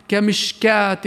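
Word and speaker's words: كمشكاة: ومثلها كذلك الواو الزائدة في كلمة كمشكاه، فهي حرف مكتوب غير منطوق في قوله سبحانه كمشكات فيها مصباح، ارجع لها وشوف كيف بيبقى مرسوم واو، الواو لا تلفظ أبداً بل نقرأها كمشكات فيها مصباح.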